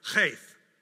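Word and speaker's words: Chaf (כ): And today's stanza all begin with the Hebrew letter Chaf. Now, I just cleared out my throat. That's how you pronounce it.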